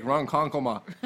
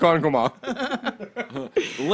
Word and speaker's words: Ronkonkoma: Lake Ronkonkoma. – Okay. We're calling it Lake Ronkonkoma. – Ronkonkoma.